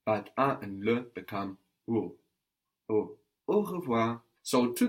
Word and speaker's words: au: Au village. – Something very strange happens here. A and le become au, which is pronounced like au. Au.